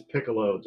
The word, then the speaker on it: Piccolo: It is Piccolo, just like the instrument we all learned about in grade school.